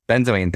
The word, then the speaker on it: benzoin: Benzoin? – Benzoin.